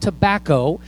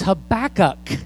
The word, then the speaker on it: Habakkuk: But there's this one guy named Habakkuk, and it's not pronounced tobacco. It's Habakkuk. And I can't imagine, though, having a little baby say, oh, let's name him Habakkuk.